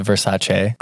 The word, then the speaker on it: Versace: You know it's pronounced Versace.